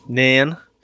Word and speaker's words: Nairn: Okay, this takes place in, how do you say this, Scottish name? – Nairn.